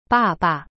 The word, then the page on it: 爸爸: ba ba